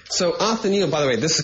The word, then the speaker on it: Othniel: Othniel. Othniel, how you pronounce the name.